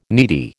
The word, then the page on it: needy: American English